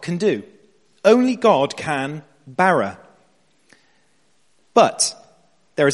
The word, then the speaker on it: bara: The fifth word in the original Hebrew is bara. Everyone say this, please. Bara. There's a slightly different pronunciation if you're a Hebrew scholar, but we're not going to get into that.